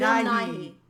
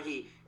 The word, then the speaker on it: Nighy: what is it, Nye? How do you say Nighy. – That? Nighy, and he's talking about the brush strokes,